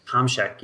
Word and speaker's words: Tomczyk: And so was Martin, Martin Tom, and I cannot pronounce his last name. – Tomczyk, yes.